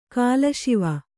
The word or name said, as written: ಕಾಲಶಿವ